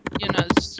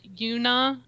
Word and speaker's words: Yuna: Yeah, I had a Twitter friend who is Korean going, they're not saying Yuna's name right. And I made her tell me how to say it right. And it's not Yuna, it's Yuna. The U is not, it's not a long U.